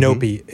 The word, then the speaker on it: Nopi: Nopi is wonderful. – How do you spell that? – Beautiful. N-O-P-I.